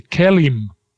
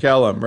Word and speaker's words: KELIM: The KELIM is how we pronounce that. – KELIM, right.